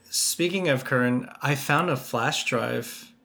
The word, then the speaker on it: Kern: I'm still working on just trying to pronounce Kern correctly. – Well, is it not Kern? – Well, it's not. It sounds different when they say it in Germany.